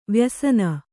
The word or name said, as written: ವಾಯುಸೇನೆ